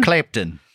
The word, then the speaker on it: Clapton: Clapton.